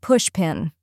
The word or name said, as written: pushpin